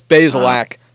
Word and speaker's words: Bazelak: How do you say the name? Bazelak? – Yes, Bazelak.